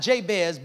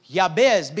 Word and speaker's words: Jabez: Now, the reason why it's important that we understand that the Hebrew pronunciation of the name is not Jabez, but it is Yabez, because Yabez is a cultural equivalent of the Hebrew word Yaseb.